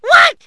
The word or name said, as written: what